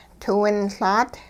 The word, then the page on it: tiwinLaat: hupa